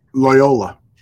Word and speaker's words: Loyola: Loyola, how do you say that, out of Maryland? – Loyola. – There you go.